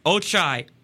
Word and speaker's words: Ochai: Ochai. O-C-H-A-I. Agbaji. A-G-B-A-J-I.